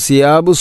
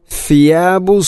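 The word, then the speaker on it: ഫിയാബു (ثِيَابُ): അലിയഹും സിയാബു സുന്ദുസിൻ എന്ന് പറയുമ്പോൾ അത് തെറ്റാവും, കാരണം ഫാഇൻ്റെ മഹ്റജ് അതിൻ്റെ ഉച്ചാരണം പൂർണ്ണമായിട്ടും മാറുന്നു. അപ്പോൾ ഫിയാബു സുന്ദുസിൻ, ഫ ഉച്ചരിക്കുമ്പോൾ നാവിൻ്റെ അറ്റം മുൻപല്ലുകളുടെ ഇരനിരകൾക്കിടയിലൂടെ അല്പം പുറത്തോട്ട് വരണം.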